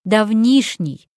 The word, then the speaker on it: давнишний: Итак, правильно, давнишний. Давнишний случай, давнишняя история, давнишний эпизод, ударение на «и».